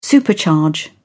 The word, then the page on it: supercharge: British English